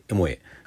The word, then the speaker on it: EMOA: 続きましての出演グループ、エモエ、 EMOA と書いて、エモエ。